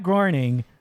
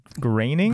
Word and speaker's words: Groening: You want to hear a really funny piece of trivia is that Matt Groening, I couldn't even say his name right. Matt Groening? – Groening, you got it.